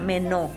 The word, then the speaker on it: meno: La palabra permanecer en el original griego significa meno, así como se escucha, meno. M-E-N-O, acento en la O, meno.